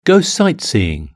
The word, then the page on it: go sightseeing: British English